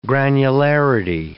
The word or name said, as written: granularity